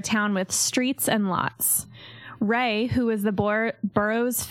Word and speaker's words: Rea: I would have even just said Ray because it's Ray. – like the first syllable of Reagan, like Ronald Reagan.